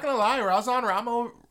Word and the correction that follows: Rajon Rondo: really rang it. I can't say his name. – Right? Yeah, let's go RR. – Rajon Rondo. – Rajon Rondo, yeah.